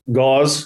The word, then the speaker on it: Gause: Gause.